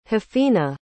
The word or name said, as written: hafina